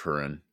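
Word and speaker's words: Húrin: Children of Hurin. – Children of Hurin, not Lord Huron.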